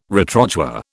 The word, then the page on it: retrochoir: British English